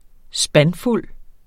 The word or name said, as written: spandfuld